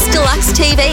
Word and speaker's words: Dluxe TV: Shakedown Radio with Chris Kaggs presents Dluxe TV.